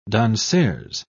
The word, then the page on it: danseuse: British English